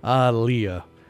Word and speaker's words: Aaliyah: That one's always tough to pronounce in person because on the record it's spelled A-H, you know, exclamation point, Leah, exclamation point. Like you just discovered someone named Leah by surprise. Ah, Leah. But it's, I guess it's Aaliyah.